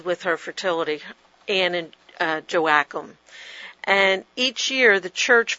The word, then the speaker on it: Joachim: Joachim. And some people say Joachim, and it just depends on where you're raised, how it's said.